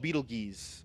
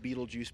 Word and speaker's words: Betelgeuse: The star is called Betelgeuse, or Betelgeuse. You can pick your pronunciation. I'm going with Betelgeuse because that's awesome.